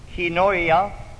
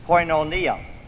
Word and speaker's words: koinonia: Brother Peter tells me it's kinoia, and I prefer the or more familiar pronunciations, koinonia. Now, I'll be using koinonia throughout our presentation, but it might not be the most accurate pronunciation.